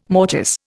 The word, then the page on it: mortice: Indian